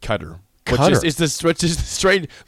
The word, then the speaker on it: Qatar: But Qatar, C-U-T-T-E-R for Q-A-T-A-R is how you pronounce it.